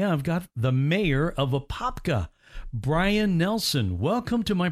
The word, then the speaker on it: Apopka: Born and raised in Apopka, yes. – Oh, that is always fun to do, because you said it right, Mayor.